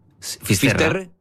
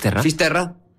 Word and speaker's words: Fisterra: Entonces, llegó a Finisterre. ¿Se dice así? – Fisterra. – Fisterra. Es como se ponen los gallegos también, en fin.